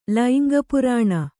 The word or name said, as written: ಲೈಂಗಪುರಾಣ